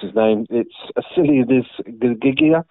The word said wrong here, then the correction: Ghiggia: I'm getting nowhere near that. It's Ghiggia, but he scored the winning goal.